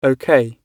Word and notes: ok: British English

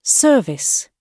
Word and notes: service: British English

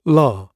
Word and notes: la: British English

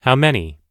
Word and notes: how many: American English

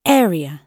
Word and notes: area: British English

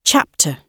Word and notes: chapter: British English

